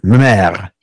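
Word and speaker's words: mémé: What is the actual pronunciation of grandmother in French? French, Canadian French. Meme? – Well, Meme? – Meme. – Meme. – If you were doing the Parisian French thing.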